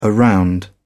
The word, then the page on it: around: British English